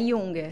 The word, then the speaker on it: Junger: Qual a pronúncia do seu sobrenome? – É Junger.